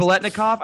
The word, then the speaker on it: Biletnikoff: It is Biletnikoff.